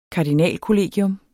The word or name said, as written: kardinalkollegium